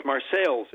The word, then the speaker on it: Marseilles: Well, it's Marseilles, Illinois. – Okay. – It's spelled the same as Marseille, France, but...